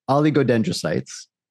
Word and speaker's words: oligodendrocytes: Jeez, I even wrote it out phonetically, audience, but I couldn't do it. Oligodendrocytes, neurons, or microglia. I'll say that again. – Oh, good. – Well, I'll try to. Astrocytes, oligodendrocytes. There we go.